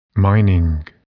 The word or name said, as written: mining